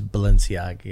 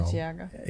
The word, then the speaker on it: Balenciaga: Maybe it was Balenciaga. Yeah, I'm saying it wrong. I know. – Balenciaga.